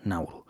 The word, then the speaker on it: Nauru: Se escribe tal y como suena, Nauru. Y veáis exactamente dónde está situada.